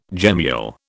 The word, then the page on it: gemmule: British English